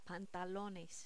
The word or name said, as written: pantalones